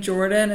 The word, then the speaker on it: Jordin: And my name Jordin is spelled with an I-N, so it's J-O-R-D-I-N.